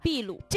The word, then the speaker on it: 秘鲁: 秘鲁它是一个专有名词，这个字的正确读音应该给的是第四声，读秘啊，秘鲁。